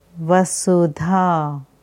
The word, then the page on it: Vasudha: Sanskrit